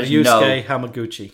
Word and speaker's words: Ryusuke Hamaguchi: Ryusuke Hamaguchi.